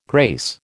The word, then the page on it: grace: American English